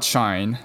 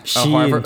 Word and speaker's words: Shein: Shein, please. – I don't even know how to pronounce it. – Shein. Right? Break it up. – But I mean... Okay, do you say Calvin Klein or do you say Calvin Klein? – Calvin Klein. – Exactly. And it's E-I-N. So... – Yeah, but it's... The company thought people would pronounce it as Shein. Shein. Yes. – Oh God. Okay. – No, not Shine.